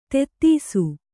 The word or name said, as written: ಧೂತಿಸು